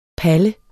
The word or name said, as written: Palle